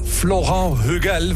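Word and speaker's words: Florent Hughel: Vandaag is geproduceerd door Florent Hugel. Want komt uit Frankrijk, hè? Dan moet je het zo uitspreken.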